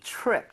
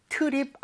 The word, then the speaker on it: trip: T-R-I-P, trip 자체가 네. – 여행이죠 발음할 때 트립 아니죠, 여러분들. TR, 이 충돌을 하게 되면, 치읓 비슷하게 됩니다. 그러니까 입 모양을 모으고 한다는 느낌으로 다시 한번 가볼게요.